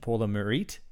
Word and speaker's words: Pour le Mérite: When they get awarded the Pour le Mérite. That's terrible pronunciation. Pour le Mérite. It's actually, ironically, a French name for a German award.